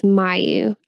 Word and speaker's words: Mayu: Kagome sees a Soul Piper, a demon that consoles the spirits of children, and she helps Mayu, a girl who is about to become an evil spirit, to pass on. – Can we codename her Mayonnaise? – Excuse me, it's Mayu. I pronounced it wrong, but no, we cannot call her Mayonnaise.